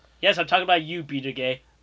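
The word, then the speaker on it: Buttigieg: It's butt gay. Like that's really what it is. Like spelling it's BUTTGEIG, butt gay.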